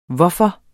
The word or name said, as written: hvorfor